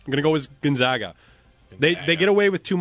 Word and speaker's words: Gonzaga: At least you said Gonzaga. They're called Gonzaga. The Zags, but it is Gonzaga.